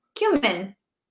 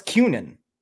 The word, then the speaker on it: cumin: Cumin. – What's cunin? – Oh, no.